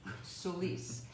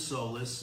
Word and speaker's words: Solis: All right, so I've been butchering Felix's name, Solis. And the only way I know how to pronounce it is because Felix had to tell me it's pronounced like police, because I kept saying Felix Solis.